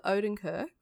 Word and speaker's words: Odenkirk: Yeah, and what's really weird is Bob Odenkirk, I think is that how you say his name? – Bang on the money.